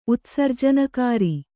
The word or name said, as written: ಉತ್ಸರ್ಜನಕಾರಿ